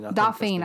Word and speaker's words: Dauphine: Dauphine.